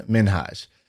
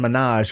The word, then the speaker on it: Minhaj: I think his name is actually pronounced Hassan Minhaj. And Hassan? Everybody calls him Hassan Minaj because it sounds awesome. And it's like Nicki Minaj.